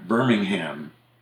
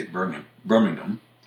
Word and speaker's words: Birmingham: And Birmingham, England, is actually, we say it's Birmingham, but the British would say Birmingham. They tend to swallow that last syllable. And in Alabama and Iowa, it's Birmingham. Mm-hmm. And if you're from Alabama, you're going to say something like Birmingham.